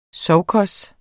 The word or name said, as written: sovkhos